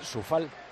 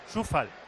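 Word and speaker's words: Coufal: ¿Cómo bueno, le has llamado tú a Koufal? Sufal. – Sufal, creo que se dice así. – Sufal. – Sufal. – Por lo menos un narrador checo que yo escuché le llamaba así al hombre.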